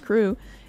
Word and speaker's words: CHRU: The Carolina Harm Reduction Union, known as CHRU, pronounced CREW, is a student-led program that provides life-saving harm reduction resources to the public of Chapel Hill.